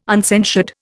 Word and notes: uncensured: Indian

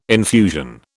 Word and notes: infusion: British English

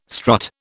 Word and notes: strut: British English

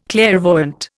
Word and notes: clairvoyant: Indian

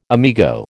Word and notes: amigo: American English

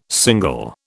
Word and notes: single: British English

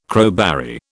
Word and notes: crowberry: British English

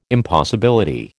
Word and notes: impossibility: American English

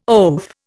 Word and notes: oaf: Indian

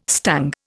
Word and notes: stung: Indian

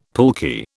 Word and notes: pulque: British English

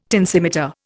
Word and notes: tensimeter: Indian

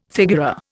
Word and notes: figura: Indian

